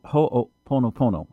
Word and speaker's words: Ho'oponopono: And it's based upon the Hawaiian prayer, actually, Ho'oponopono. Ho'oponopono.